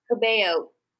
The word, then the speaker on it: Cabello: The the um, I'm not sure if I'm saying her name right, Camila Calejo or Cabello. There's like two, Cabello. right? Cabello. I love that.